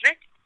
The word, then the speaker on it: Reck: Pues mayormente en mi Instagram, que ahí es donde estoy más activo, eh, me pueden encontrar como Camille Rec. Eh, mi apellido se escribe R-E-C-K, por ser Entonces también por Facebook, por Camille Rec Art, todo junto.